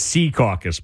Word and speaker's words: Secaucus: Okay, but what's the right way to pronounce it? – Hudson County people probably say Secaucus. – It is. – I'm from Hudson County originally. – Yep, exactly. It's Secaucus, but a lot of people say Secaucus.